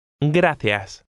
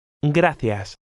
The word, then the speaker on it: gracias: Gracias. Gracias. – Did you pronounce the C like an English TH? In Castilian Spanish, the letter C has this sound when it's followed by an E or an I.